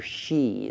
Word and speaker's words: Hsi: One of the great classic biographies of all time is the biography of Pastor Shee. It's spelled H-S-I.